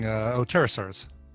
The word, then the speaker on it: pterosaurs: petrosaurs, am I saying that right? Pterosaurs and modern birds. – Sorry, I was looking. Pterosaurs. – The P is not in there.